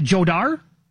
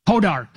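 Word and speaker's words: Jódar: He's got, is it Jodar? Hodar. The J is silent.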